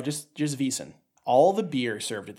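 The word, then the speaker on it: Wiesn: They actually call it, I'm going to try to pronounce this properly, Wiesen. – Wiesen. – Wiesen, which is the German word for meadow.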